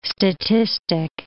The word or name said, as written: statistic